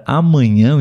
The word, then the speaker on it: amanhã: No an do Sim. amanhã é a sílaba tônica, né? Sim. Amanhã. O tio também representa a sílaba tônica, né? – Uhum.